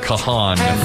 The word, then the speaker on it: Kahan: I said to BB Shay, how do I pronounce his name? Because it's spelled like Kahan. He goes, it's Kahan.